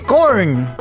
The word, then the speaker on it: Korn: Se escribe con K de corn. De cork. Corn. K-O-R-N. a N. N, sí. Ok, ok. Cork. Corn. Corn. K-O-R-N.